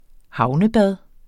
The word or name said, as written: havnebad